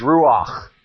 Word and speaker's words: Ruach: In Hebrew, in the Old Testament, Spirit is Ruach. R-U-A-C-H in English. The transliteration of that Hebrew word is spelled that way in English.